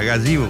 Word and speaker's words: Gazebo: Gazebo. – Eso es. – Gazebo, bro.